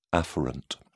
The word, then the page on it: afferent: British English